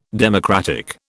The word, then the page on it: democratic: British English